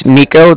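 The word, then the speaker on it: Niko: My name's Nico. That's N-I-K-O. And I'm calling with the Bank of Cardiff, and that's spelled C-A-R-D-I-F-F, as in Frank, Frank.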